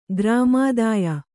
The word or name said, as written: ಗ್ರಾಮಾದಾಯ